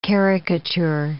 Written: caricature

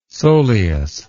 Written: soleus

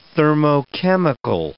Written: thermochemical

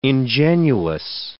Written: ingenuous

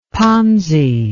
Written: ponzi

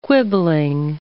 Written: quibbling

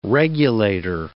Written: regulator

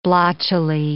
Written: blotchily

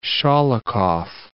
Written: Sholokhov

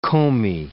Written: comae

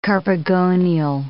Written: carpogonial

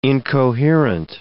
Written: incoherent

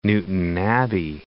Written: Newtownabbey

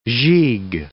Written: gigue

